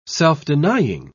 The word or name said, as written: self-denying